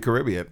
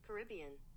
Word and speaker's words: Caribbean: So Caribbean is the American pronunciation. I'll play it. – Caribbean. – Right? Caribbean? – Mm. The other way is the British. – Caribbean. – That's the American pronunciation, and the British pronunciation is this.